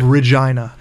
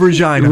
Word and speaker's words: Regina: I am from the city of Regina. – Sorry? – The city of Regina. – Regina.